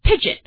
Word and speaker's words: pidgin: This phenomenon is talking in such mixed languages that is called linguistically pigeon. It sounds like the word pigeon as in the bird, but it's actually spelled like this, P-I-D-G-I-N.